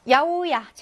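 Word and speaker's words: 야오야 (八百屋): 야오야 채소가게. 특이하게 읽히죠. 야오야라고 읽습니다.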